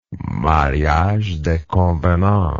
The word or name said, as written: mariage de convenance